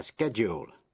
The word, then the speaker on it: schedule: G-R-A-D-U-A-T-E, graduate. Pendulum, P-E-N-D-U-L-U-M, quả lắc đồng hồ. Và schedule, schedule là thời biểu. Vậy bốn chữ, procedure, graduate, pendulum, schedule, trong đó âm D phát âm là J.